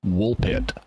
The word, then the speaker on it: Woolpit: And that means that in getting ready to make the P sound in pit and then the consonant afterward, the vowel afterwards, it's very easy for the F sound in wolf to get lost. And at least it can be, unless you're really precise in your pronunciation. And so the two words blend together. Wolf pit becomes Woolpit.